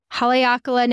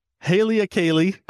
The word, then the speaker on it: Haleakala: I kept calling it Halia Kala. I was just putting the emphasis on the wrong syllable.